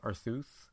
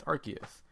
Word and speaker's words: Arceus: Like, until it comes out in the anime, like, there's that Pokemon Arceus that I thought it was just pronounced Arceus. But... – Like Dr. Seuss? – Well, no, like Zeus, because he's like the god of all Zeus.